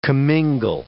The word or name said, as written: commingle